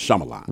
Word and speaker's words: Shyamalan: It wasn't to make fun of him, but it was, I know Shyamalama Ding Dong. That way, I know when I see his name, I just know it's pronounced Shyamalan.